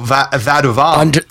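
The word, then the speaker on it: Vadouvan: What? Vaduvin? Oh, Vaduvan. – Vaduvan? – Yeah, that's how I would pronounce it.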